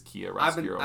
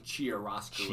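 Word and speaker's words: Chiaroscuro: We were kind of pronouncing it differently, but it is Kia Roscuro. – I've been saying Chia Roscuro. – Chia, yeah, like a Chia pet. Yeah. But it's Kia, like the car.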